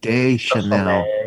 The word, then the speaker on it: Deschanel: It's like the perfume Deschanel. – Deschanel.